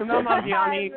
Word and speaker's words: Kumail Nanjiani: Like that story, and the presenters, and he gave that's Kumail Nanjiani, he's the writer, right? Is that, did I say his name? Sort of. – Kumail Nanjiani, co-writer, yeah, with his wife.